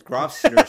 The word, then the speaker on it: Grosvenor: We have a Grosvenor Street in London. The way you pronounce it, like everyone who comes to London, it's Grosvenor Street. It's like, no, it's pronounced Grosvenor Street.